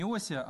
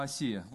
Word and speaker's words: Осия: Не Осия, а Осия.